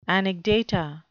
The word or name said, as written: anecdata